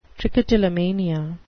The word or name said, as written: trichotillomania